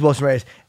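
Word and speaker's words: Wilson Reis: I think it's Reyes. How you say his name? As the co-main, and then I think it's Reyes, Wilson Reyes. – It is Wilson Reyes, and then he had to pull out due to an undisclosed injury.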